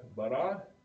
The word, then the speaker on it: bara: It is the word bara. And it is spelled in English B-A-R-A.